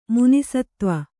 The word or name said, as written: ಮುನಿಸತ್ತ್ವ